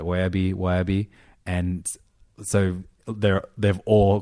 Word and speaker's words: Wehbe: But they have a lot of friends who have the surname of Wahhabi. – Wahhabi. – Which is spelt, or most of the time spelt W-E-H-B-E.